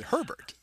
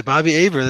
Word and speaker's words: Hebert: Bobby Hebert. You know, that's right. – If some people don't pronounce it Herbert, they pronounce it Hebert, like Bobby Hebert.